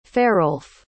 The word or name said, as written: farolf